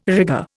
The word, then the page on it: rigger: Indian